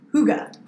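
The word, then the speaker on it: hygge: A Nordic tradition called, well, I thought it was Higgy, because it's H-Y-G-G-E, and I was like, oh my God, has anybody heard of this concept of Higgy? Higgy, Higgy, Higgy, Higgy, Higgy. And nobody knew what I was talking about. And then I realized, I learned the other day that it's pronounced Huga.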